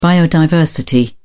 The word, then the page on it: biodiversity: British English